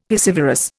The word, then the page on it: piscivorous: Indian